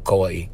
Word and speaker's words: Kauai: Or Kauai, as we say, right? Supposed to pronounce Kauai.